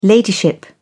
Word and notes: ladyship: British English

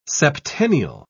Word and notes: septennial: British English

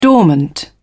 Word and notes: dormant: British English

